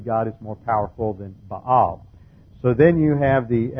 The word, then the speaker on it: Baal: But another thing is going on here, and that is that the state religion is now Baal worship, and Baal, as it's pronounced in the Hebrew, Baal is the god, the storm god.